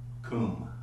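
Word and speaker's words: kum (קום): And that word up here for arise is kum. It's a K-U-M, a Q-U-M, but it's pronounced K-O-O-M, you know.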